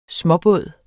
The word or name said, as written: småbåd